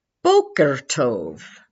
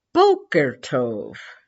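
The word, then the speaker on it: Boker Tov (בוקר טוב): It's pronounced Boker Tov, Boker Tov, and the response, one of the many responses, is Boker Or, and that means morning light.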